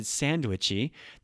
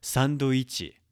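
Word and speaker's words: サンドイッチ: For example, if I said sandwichy, that's not correct. Sandoichi.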